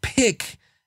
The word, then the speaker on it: PIQ: The company is P-I-Q. It's pronounced Pick.